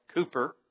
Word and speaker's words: Cowper: We would think that's how you would say it, reading it, C-O-W-P-E-R, but I believe it was said Cooper rather than Cowper.